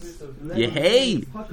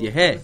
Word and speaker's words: Yehei: Yehei. There's no Yud over there. It's Yehei.